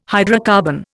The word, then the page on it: hydrocarbon: Indian